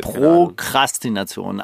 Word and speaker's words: Prokrastination: Prokrastination, glaube ich, ohne R, oder? – Ich habe es immer mit zwei R's ausgesprochen, vielleicht war es falsch die ganze Zeit. – Prokrastination, also es ist anders als bei Aristokratie, da kommt nämlich kein R nach dem T.